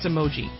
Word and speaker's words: emoji: You always say it wrong. It's emoji.